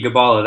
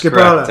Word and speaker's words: Gibala: Yeah, hard G, Gibala, that's Gibala. Correct.